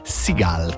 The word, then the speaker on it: Seagal: Seagal, credo.